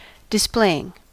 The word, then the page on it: displaying: American English